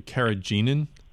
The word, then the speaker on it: carrageenan: There is a product known as, let's see if I pronounce this correctly, carrageenan.